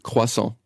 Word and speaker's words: Croissant: I want to say it like the way you say it. Croissant. I say it wrong. Say it again. – Croissant. – Croissant? – Yeah. – Reviews. See, if I concentrate, I can do it.